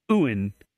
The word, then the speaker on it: yuan: The woman refused, so the man demanded 300 Chinese yuan, which, yes, Brian, I looked up the pronunciation. – What was Chinese currency, and it's yuan, which is about $47.